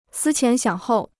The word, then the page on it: 思前想后: sī qián xiǎng hòu